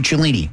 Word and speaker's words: Cellini: You download, again, the Fliff app, F-L-I-F-F. Use the promo code Cellini, which would be my last name, and that's spelled C-E-L-L-I-N-I, to get started.